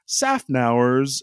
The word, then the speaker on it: Szafnauer: Um, uh, but first of all, I, I wanted to to talk about uh, Otmar, Szafnauer's uh, uh, his departure from Aston Martin um, was a was a pretty.